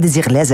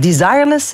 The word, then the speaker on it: Desireless: Zo werd er gesproken tegen mij, omdat ik desireless zei, terwijl ik eigenlijk desireless moest. – Wel, bij deze, ik heb het opgezocht, Google zegt dat je het op zijn Engels moet uitspreken.